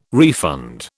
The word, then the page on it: refund: British English